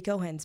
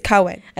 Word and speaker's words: Cohen: You have to start saying his name right if he's going to have you on. – I'm so sorry. – It's Cohen. – I love that you support him. – I know. It's not about him. It's not personal. He's Jewish. – Yeah. – You got to say Cohen, right? Also, look, you're working in entertainment. If you can't pronounce the word Cohen, it's not going to serve you well.